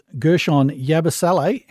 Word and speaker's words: Gershon Yabusele: He was playing for Partizan Belgrade when he was attacked by Real Madrid's Gershon Yabusele. Hey, how's that for pronunciation? – Hey, you've done well.